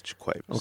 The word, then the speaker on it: JaQuavis: Wait, I said it right. – JaQuavis Coleman. – JaQuavis. – JaQuavis. – Yeah. – JaQuavis. We're sitting here with JaQuavis Coleman. – Three syllables, JaQuavis.